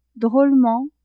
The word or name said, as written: drolement